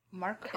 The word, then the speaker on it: Marquis: Is that how you pronounce that? – Marquis. – Marquis? – Marquis? – In Final Fantasy XII, they said Marquis. So that I was like, that's not how you say it. – Actually, that is a valid pronunciation, apparently.